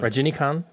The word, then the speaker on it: Rajinikanth: The TH might be silent. – Rajinikanth, okay.